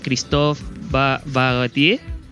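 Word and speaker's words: Christophe Barratier: De hecho, esta película fue dirigida por eh, Christophe Bagatier. Así se pronuncia, ahí, estoy puliendo el francés.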